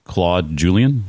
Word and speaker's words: Claude Julien: Well, the last coach I want to ask you about, I think I can pronounce this name accurately, we'll see, is Claude Julien. Am I saying that right? – Okay. Yeah.